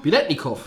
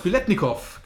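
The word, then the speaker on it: Biletnikoff: Ja, das, ich hoffe, dass ich ihn richtig ausspreche. Fred Spilentikov, oder? Biletnikov. Biletnikov, genau. Ja.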